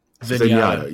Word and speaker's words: Zenyatta: Like you said, like, if someone doesn't know how to play, let's say Zendaya. How do you say his name? – Zenyatta, yeah, like a bad Zenyatta, man, he's so soft.